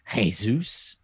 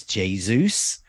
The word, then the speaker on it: Jesus: Haaland, you may have mentioned, notice I said there, not Haaland, Haaland, and not Jesus, it's Jesus.